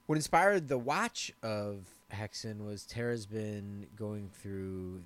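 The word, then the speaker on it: Häxan: And I know I pronounced it Haxen when we said what we were going to do this week, but the correct pronunciation is Hexen.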